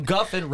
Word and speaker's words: Guffin: Malcolm Guffin rhymes with muffin, rhymes with puffin.